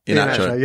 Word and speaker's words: Iheanacho: Why Vardy and however you pronounce it, Iheanacho. – Iheanacho, yeah. Iheanacho.